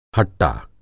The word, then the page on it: हट्टा: Hindi